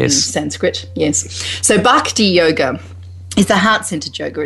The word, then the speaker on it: bhakti: Bhakti. Oh, B-H-A-K-T-I. – Yep. B-H-A-K-T-I. – I suspect it's probably a long A as well. It's probably and it's an aspirated B, so it's probably bhakti.